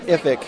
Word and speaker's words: IFIC: I'm speaking with David Schmidt, and David is President and CEO of the International Food Information Council, or we might pronounce it IFIC here in this interview, David.